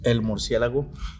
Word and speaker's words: El Murciélago: One of the early stars was El Murciélago. Did I say that right? How bad did I fuck that up? – El Murciélago.